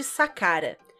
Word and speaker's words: Saqqara: E o outro é Os Segredos de Sakara. Sakara se escreve S-A-Q-Q-A-R-A.